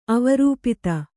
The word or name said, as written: ಅವರೂಪಿತ